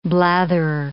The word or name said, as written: blatherer